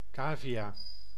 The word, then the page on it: cavia: /ˈkaːviˌjaː/